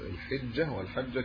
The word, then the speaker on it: ذو الحجة: الأشهر الحرم هي ذو القعدة وذو الحجة أو الحجة والمحرم ورجب هذه الأربعة هي الأشهر الحرم يعني ذو القعدة بنطقها الحجة والحجة كلاهما صحيح كذا دي ما فيش فيها ذو القعدة.